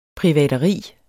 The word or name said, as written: privateri